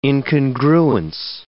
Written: incongruence

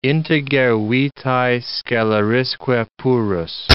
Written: integer vitae scelerisque purus

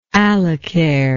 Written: allocher

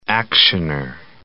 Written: actioner

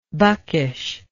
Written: buckish

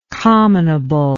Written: commonable

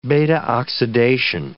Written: beta-oxidation

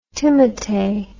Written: timotei

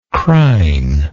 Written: crine

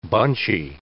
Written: bunchy

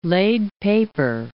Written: laid paper

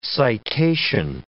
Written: citation

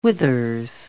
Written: withers

